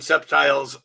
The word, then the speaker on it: septiles: Also in February from Rick, we're going to be having the long awaited septiles and quintiles class.